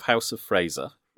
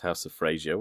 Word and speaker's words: House of Fraser: So if you think, right, if you think of House of Fraser, which is often pronounced House of Frasier, which drives me bananas.